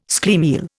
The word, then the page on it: schlemiel: Indian